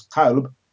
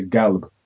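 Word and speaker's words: qalb (قلب): So the Arabic word for heart, which is qalb, they wouldn't say qalb, they would say galb.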